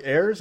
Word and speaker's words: Ayers: But you recently had a podcast with the former Green Bay analytics guy, Mike Ayers. Am I saying that right? – Yes. Yep.